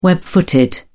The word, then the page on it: web footed: British English